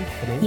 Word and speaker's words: Benaim: Benaim? Yeah. Benaim.